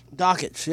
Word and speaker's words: Dakich: I don't know how to say this guy's last name. Don't At Me with Dan Dykin. Dykin, D-A-K-I-C-H. – Dan Dockich. – Dockich, yeah, okay, that's it.